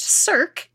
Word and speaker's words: cirque: By the way, if you didn't find out since, a circ, pronounced circ, it's spelled C-I-R-Q-U-E, is pronounced circ, as in C-I-R-C,